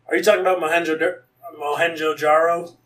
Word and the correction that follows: Mohenjo-Daro: Let me try to double check that pronunciation. Yeah, I'm not wrong. It's Mohenjo-Daro.